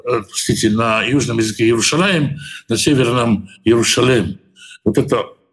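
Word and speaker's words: Иерушалаем (ירושלים): Почему Иерушалаем стоит в двойственном числе? Это южное произношение. Если вы посмотрите, в Торе в большинстве случаев э, написано Иерушалем.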